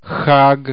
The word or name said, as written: chag